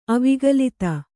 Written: ಅವಿಗಲಿತ

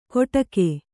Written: ಕೊಡಕೆ

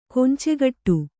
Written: ಕೊಂಚೆಗಟ್ಟು

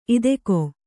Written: ಇದಕೋ

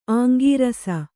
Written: ಅಂಗರಸ

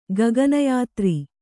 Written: ಗಗನಯಾತ್ರಿ

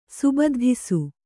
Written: ಸುಬದ್ಧಿಸು

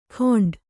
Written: ಗೊಂಡ

